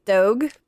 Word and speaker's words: Dogue: It's like, it's Vogue, but it's spelled with like a D, like, so it's Dogue.